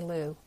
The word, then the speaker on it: Liu: Hello, my name is Jenny Liu. Liu is spelled L-I-U, but a close enough pronunciation might rhyme with Liu as in L-O-U.